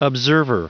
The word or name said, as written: observer